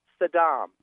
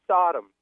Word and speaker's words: Saddam: And in such an ancient city as Baghdad, it really annoys me the way these politicians, you know, strategically mispronounce Saddam. You know, the Americans say Sodom.